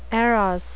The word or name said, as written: eros